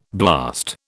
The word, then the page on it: blast: British English